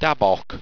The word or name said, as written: dabok